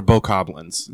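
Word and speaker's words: Bokoblin: she corrected me from Bokoblins to Bokoblins. – It's Bokoblins or Bokoblins. Yeah. It's like a long O, basically.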